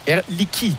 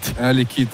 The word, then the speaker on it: Air Liquide: Aber wie wir unsere Firma aussprechen, habe ich auch gelernt. Air Liquide. – Air Liquide, das stimmt.